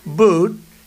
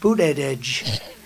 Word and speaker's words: Buttigieg: And they said, Buttigieg, the word edge. That's the way it's pronounced, Buttigieg.